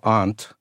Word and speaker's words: aren't: And then we had two separate camps, those who pronounced the A as ah, so aunt, and linguist David Crystal says that by what's called orthographic analogy, that aunt became similar to when I say aren't in a posh English accent, let's say.